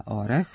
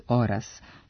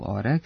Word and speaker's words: orah: Imenica orah, suprotno mnogim ubeđenjima, ne posjeduje normativno ispravan dublet oras, što nam potvrđuje i rečnik srpskog jezika koji beleži samo varijantu orah sa kratkouzlaznim akcentom na prvom slogu.